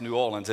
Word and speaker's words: New Orleans: When I was in seminary, at New Orleans Seminary, and from Louisiana, that's how you pronounce New Orleans. It's one word, not two, New Orleans.